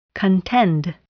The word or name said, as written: contend